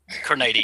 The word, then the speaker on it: Criniti: Criniti, yeah, that's right.